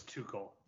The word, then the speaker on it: Tuchel: Yeah, I mean, touche. – That's not how you pronounce it. It's Tuchel.